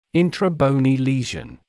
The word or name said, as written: intrabony lesion